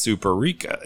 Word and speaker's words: Superica: So it's Superica is how it's pronounced.